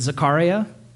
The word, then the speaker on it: Zechariah: My rabbi colleague pronounces it Zechariah, which sounds way cooler than Zechariah, doesn't it?